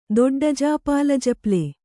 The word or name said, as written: ದೊಡ್ಡಜಾಪಾಲಜಪ್ಲೆ